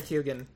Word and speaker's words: Keoghan: I'm perfectly happy to go with Barry Keoghan. – Cool. And thank you for saying his name correctly. Keoghan? – I don't know.